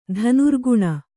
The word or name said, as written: ಧನುರ್ಗುಣ